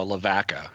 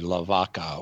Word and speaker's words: Lavaca: Yeah, here in Arkansas, we have Lavaca, pronounced in Arkansas, Lavaca. But it's not pronounced the Spanish way, Lavaca or the cow.